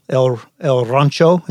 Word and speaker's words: El Rancho: your high school, El Rancho. Is that how you say it? – Correct.